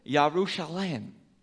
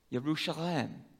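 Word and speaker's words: Yerushalem (ירושלים): The word for Jerusalem, and I'm going to butcher this one a little bit, Yerushalem. Yerushalem. And it's the same ending. It's a plural ending.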